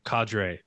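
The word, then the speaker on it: Qadree: Is it Quadre or Cadre? – I think Cadre? It's just Cadre.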